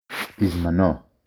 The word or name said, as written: בזמנו